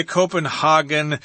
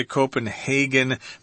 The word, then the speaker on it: Copenhagen: Anne Marie Johansson, and she is a 10-year-old girl residing in Copenhagen, Denmark. That's the capital city of Denmark. Some people say Copenhagen, some people say Copenhagen.